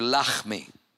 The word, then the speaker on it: Lahmi: But it's literally pronounced in Hebrew, Lahmi.